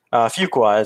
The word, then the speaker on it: Fuqua: Fuqua is how Fuqua.